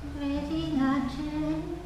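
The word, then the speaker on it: Regina Caeli: No, re, no empezar el acento en la primera sílaba. Sino, a menos que lo tenga, ¿no? Pero. Regina Cheli.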